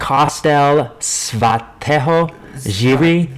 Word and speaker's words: Kostel Svatého Jiří: How do you say that? – Kostel Svatého Jiří. Yeah, K O S T E L S V A T E H O J I R I.